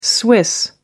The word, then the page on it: swiss: American English